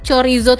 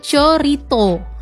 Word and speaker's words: chorizo: Terus juga pengucapan dari chorizo tadi nih teman peradua adalah yang benar ternyata ya, yaitu chorito. Lain ya. Itu di Spanyol. Jadi namanya chorito. Kemudian kalau di Amerika Serikat namanya chorizo.